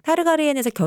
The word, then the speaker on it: 타르가리엔: 라이가르 타르엔이랑 리아나 스타크가 정식 결혼을 했다. 원래 그때 라이가르 타르엔 발음이 안 돼. 라이가르 타르가리엔이 그 마르텔 가문의 네.